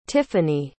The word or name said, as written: tiffanie